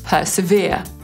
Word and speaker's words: persevere: Można go wymówić na dwa sposoby. Brytyjczycy powiedzą persevere, czyli zaakcentują trzecią sylabę.